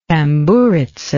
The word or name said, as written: tamburitza